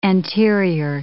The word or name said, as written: anterior